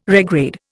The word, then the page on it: regrade: Indian